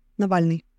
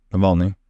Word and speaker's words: Navalny: Navalny. – Navalny.